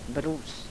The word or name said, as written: brus